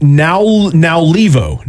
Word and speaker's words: Naulivou: The phonics next to his name, I'll just read it verbatim as it sounds on the phonics. Now Levo. It's spelled N-A-U-L-I-V-O-U. So understandably, when you look at it, you have to kind of pause and think for a second.